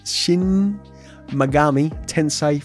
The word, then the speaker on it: Shin Megami Tensei: Then a game that piqued my interest is Shin Megami Tensei 5.